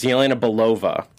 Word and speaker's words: Yelena Belova: It's Yelena Belova.